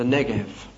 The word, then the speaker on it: Negev: Be pronounced as a V, the Negev.